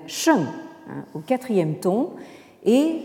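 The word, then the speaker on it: sheng (聲): Et cette partie haute du caractère, vous la retrouvez Hein, euh, dans euh, le mot qui désigne le son, hein, euh, qui se prononce en chinois moderne sheng.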